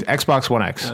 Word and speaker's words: Xbox One X: Okay, well, first, if I had the money to buy an Xbox One, I probably wouldn't get an Xbox One or Xbox One X. Did I say that right? – Yeah. – Is there another X? – No, there's one X. Xbox One X.